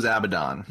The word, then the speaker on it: Abaddon: I thought it was Abaddon. – No, it's Abaddon. That's how the show writers keep saying it, how the book writers keep saying it.